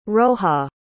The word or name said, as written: roja